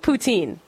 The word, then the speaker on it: poutine: And then, what was the third dish I can't pronounce or say or remember? – I think poutine. – Yes. – I'm pretty sure.